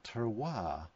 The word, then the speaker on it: terroir: The first is simply new make that is designed to express the terroir of the grains.